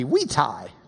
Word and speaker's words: vitae: Curriculum vitae. Or if we were pronouncing it in Latin, it'd be vitae, because their V's were W's.